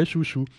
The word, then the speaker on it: Chew: Euh, ça s'écrit S-H-O-U, C-H-E-W. Alors moi je prononce Su, c'est C-H-E-W, Hein, à l'anglaise, donc ça fait chouchou.